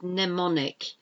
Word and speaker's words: mnemonic: Mnemonic, it's mnemonic, how you pronounce things, M-N-E-M-O-N-I-C, mnemonic, you don't pronounce the first M, it's silent.